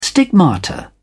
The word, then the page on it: stigmata: British English